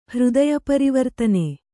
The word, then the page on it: ಹೃದಯಪರಿವರ್ತನೆ: Kannada